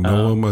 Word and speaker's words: Noem: Governor Noem, I think is how you say her last name. – N-O-E-M.